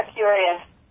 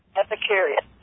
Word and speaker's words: Epicurus: So anyway, that came from, that was right off the internet from, how do you pronounce that word? Epicurus? Curious? – Yeah, Epicurus. – Epicurus.